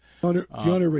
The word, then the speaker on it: Keanu: Am I saying his name right? Keanu Reeves? – Keanu Reeves. – Keanu. That's the way you say Keanu.